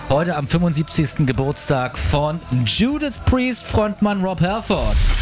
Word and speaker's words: Judas Priest: Abgesehen davon, dass ich ihn noch gar nicht kannte von Judas Priest. Super geil. Den Song muss ich mir mal anhören. Die Band heißt übrigens bei meinem Sohn Judas Prince. Ja, weil er die damals nicht richtig aussprechen konnte.